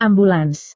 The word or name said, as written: ambulans